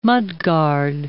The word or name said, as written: mudguard